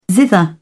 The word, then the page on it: zither: British English